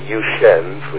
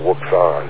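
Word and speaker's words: Wouxun: But there's the Yushens, which a lot of people call Wuxian. That's how it's spelled, but it's Yushen or Oshen or something like that.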